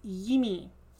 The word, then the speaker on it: Yimi: And it is Yimi.